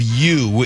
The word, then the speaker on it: ewe: The spelling of ewe in this case is E-W-E. Ewe. E-W-E spells ewe.